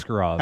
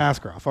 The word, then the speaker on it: Askarov: Yeah, like I mentioned there, like you know, Skarov, Askarov. – Mm. How, which way am I saying it right? – Uh, Doesn't matter. Askarov. Askarov.